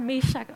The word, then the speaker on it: Meshach: Meshach og Abednego. Det er helt rigtigt udtalt, det kan jeg lige så godt bare sige med det samme.